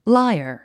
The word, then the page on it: liar: lǽiə r; ライア